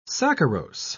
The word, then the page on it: saccharose: British English